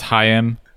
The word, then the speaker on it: Haim: And Wikipedia says it's pronounced Haim, which I wonder if it's a Hebrew name, because that's the their Israeli born father Mordecai Haim.